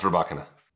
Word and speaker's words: Rybakina: It used to be Rybakina, now it's Rybakina. – Okay, now it's Rybakina. Takes out Alexandrova in the final, 6-2 in the third. And Rybakina now just sits 15 points behind Mira Andreeva in the race.